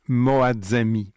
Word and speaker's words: Moazzami: H-A-N-T-E accent aigu E. Donc l'autrice se nomme Chagayeg Moadzami. Chagayeg, ça s'écrit S-H-A-G-H-A Y-E-G-H. Et Moadzami, ça s'écrit M-O-A-Z-Z-A-M-I.